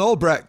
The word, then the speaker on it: Albrecht: Nolan Albrecht. What's up, man? Did I say that right? – Yeah, Albrecht.